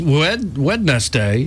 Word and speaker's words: Wednesday: In a press release, Wednesday, is that how you say it? – That's how I say it.